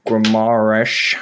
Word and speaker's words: Guimarães: Guimarães. I think you have to say it like that.